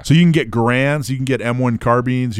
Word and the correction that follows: Garand: Actually, it's pronounced Garand. So I've been told.